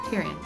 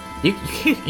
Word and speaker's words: Tyrion: Tyrion. I said it right? – Yes, okay. Tyrion.